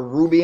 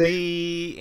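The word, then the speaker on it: Robby: So you got DJ Moore, Robbie Anderson, or Ruby Anderson, how do you say? – Robbie Anderson and Samuel.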